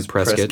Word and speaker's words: Prescott: It's spelled Prescott, but we're all redneck, so we say Prescott.